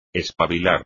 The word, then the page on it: espabilar: espagnol